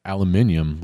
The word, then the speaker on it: aluminium: I think he pronounces it aluminium like they do over across the pond, as it were.